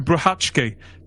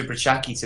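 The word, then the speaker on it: Brachacki: Can I just say the name Peter Bruhatsky? Just because I'm really pleased with myself for learning how to pronounce it after 30 years. – I'm still not convinced. It looks like Peter Bruhatsky to me, but there we go. What do I know?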